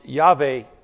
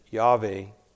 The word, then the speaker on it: Yahweh: And it is best pronounced Yahweh, also known as the Tetragrammaton, a new word we get to learn this year. And it simply means those four letters, YHWH. And the vowels are then supplied to come up with that pronunciation, Yahweh.